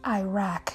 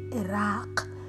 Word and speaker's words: Iraq: This man, this leader, this president in a country, many people would pronounce it as Iraq, but it's actually Iraq.